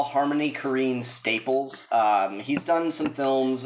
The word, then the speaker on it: Korine: Korine. As in you will careen off the road mysteriously if you say his name wrong again.